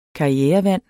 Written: karrierevand

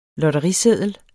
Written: lotteriseddel

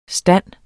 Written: stande

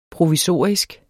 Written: provisorisk

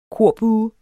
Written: korbue

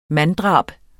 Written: MANDDRABENES

